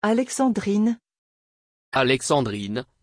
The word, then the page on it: Alexandrine: French